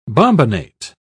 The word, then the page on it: bombinate: British English